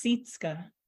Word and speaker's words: Sitska: It's Sitska. – Sitska. – Like you with the E.